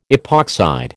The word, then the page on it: epoxide: American English